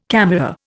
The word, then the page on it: camera: Indian